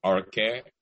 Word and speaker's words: Orkeh: And I've been called all forms and iteration of Orkeh, from Orkeh to Okere to that.